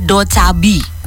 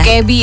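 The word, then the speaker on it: Dokkaebi: Dan judulnya adalah Do Chabi. – Ya. Do Kebi ya. – Do Chabi bacanya gimana? – Karena artinya goblin. Kalau goblin berarti Do Kebi.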